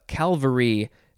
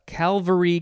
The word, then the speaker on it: Calvary: And I think I said this wrong. Calvary. I think I put another L in there.